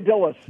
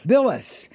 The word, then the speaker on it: Bilas: Oh, Jay Bilas. – Bilas.